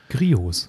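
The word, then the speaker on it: Griot's: Also Grio, Grios wird es übrigens ausgesprochen. Griots. Griots, ja. – Also das Geile ist ja. stumme T.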